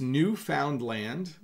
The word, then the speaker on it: Newfoundland: Game Account says, how do you really pronounce Newfoundland? So It's Newfoundland. That's a question coming out of the chat, but we say Newfoundland.